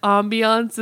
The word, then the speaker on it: ambiance: Well, you also think that ambiance is pronounced ambience. – It is ambient. – It's. Well, ambient light, but ambiance. It's not ambience.